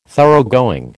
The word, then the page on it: Thoroughgoing: American English